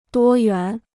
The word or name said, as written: 多元